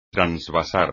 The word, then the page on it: transvasar: espagnol